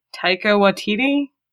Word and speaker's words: Taika Waititi: But I may also suggest, I cannot pronounce his name for sure, but is it Taika Waititi? The guy who directed Ragnarok? – That's certainly close enough.